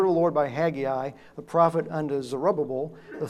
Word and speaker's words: Haggai: All right, let's turn to Haggai, or Haggai, as my son Austin told me I wasn't pronouncing it right.